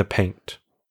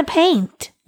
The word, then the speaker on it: paint: You're not pronouncing the T at the end of the word. It sounds like you're saying go hard in the pain. Which go hard in the paint.